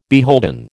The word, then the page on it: beholden: American English